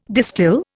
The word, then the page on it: distil: Indian